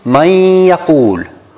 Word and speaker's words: من يقول: We should put an X on the noon and put shadda on the ya. So we pronounce it man yaqul.